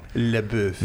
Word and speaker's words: LaBeouf: LaBeouf.